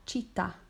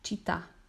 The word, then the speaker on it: città: City or town is la città, so obviously it sounds like city kind of, C-I-T-T-A, città, okay, with an accent on the final A, città. Il paese, P-A-E-S-E, paese, okay?